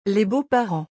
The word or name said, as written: Les beaux-parents